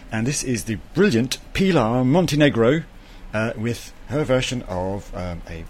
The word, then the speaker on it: Pilar Montenegro: Pilar Montenegro.